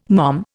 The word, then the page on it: mom: Indian